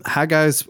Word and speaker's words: Haggai: Um, and so Haggai. pronouncing it. Say what? Good job pronouncing.